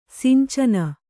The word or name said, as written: ಸಂಚನೆ